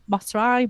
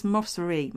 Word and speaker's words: Mosseri: Um, and um, they are, as we know, um, Adam Mosrai, Masari, Mosrai. We said Mosrai. We decided it's, that was going to be his name. I listened to the pronunciation over and over this week assuming that's right. And it's it's either Mossari. It's Mossari. Mossari. It's an E. – Mossari. It's the I is an E.